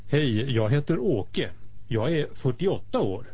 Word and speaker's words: jag är: Добавим, что шведы часто не произносят, проглатывают последнюю согласную, и вместо «jag är» слышно «я е».